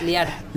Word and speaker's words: Liar: ¿Pronunció bien Liar? Liar. – Liar. – Liar, perdón. – Como mentiroso. – Exacto. Es como mentiroso, como exactamente.